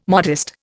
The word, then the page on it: modest: Indian